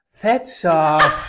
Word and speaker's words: Thatcher: Thatcher. – Das ist ein deutsches Wort. Was ist das denn? – Margaret Thatcher war die Premierministerin.